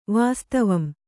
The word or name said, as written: ವಾಸ್ತವಂ